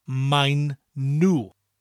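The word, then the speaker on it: maen nhw: First of all, they are is mein nu. Mein nu. Spelled M-A-E apostrophe N, mine, and nu is spelt N-H-W.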